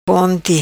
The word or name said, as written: Ponti